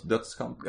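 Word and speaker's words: Dødskamp: I'm gonna like butcher the way you say it. Dodscamp? – It's close. Dodscamp. – Okay. There's like a different letter in there that I don't know how to pronounce. So, yeah, the O with the line through it.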